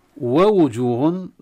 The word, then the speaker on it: ووجوه: ووجوه لا ننسى النطق في الكلمة هذه في ثلاث واوات على فكرة، لا ننسى أن ننطق بالواوين بحركتين كاملتين، واو مفتوحة وواو مضمومة. ووجوه لأن البعض يسرع فيخطف الحركة خطفا يقرأ ووجوه يعني ما ينطق يم الواو الأولى غير مكتملة أم الثانية غير مكتملة.